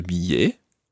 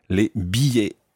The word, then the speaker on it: billets: Just watch billet. – Billet. – You don't actually pronounce an L sound in there. Les billets.